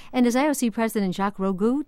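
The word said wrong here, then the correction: Rogge: Roga, said security has to be even more of the minds of everyone today.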